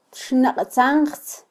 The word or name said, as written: txnaqtzanjtz